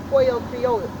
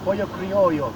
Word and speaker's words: pollo criollo: So when we go by Super Maxi, in Cuenca or Guayaquil or anywhere in Ecuador, be sure to pick up some pollo criollo. How do you say that word again? – Yeah, pollo criollo.